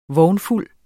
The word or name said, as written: vognfuld